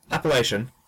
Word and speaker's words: Appalachian: So as the example, I have Appalachian Spring, Appala. How do you pronounce that? – Appalachian.